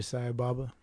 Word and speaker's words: Sai Baba: Sai Baba, did I say that right? – Yeah, Satya Sai Baba.